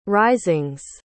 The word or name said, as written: risings